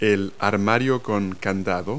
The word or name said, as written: locker